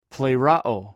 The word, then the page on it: plhrow: greek